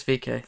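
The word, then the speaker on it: VeeKay: It's VK. That's VK.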